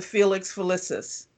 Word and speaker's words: Felix Felicis: Felix Felicis.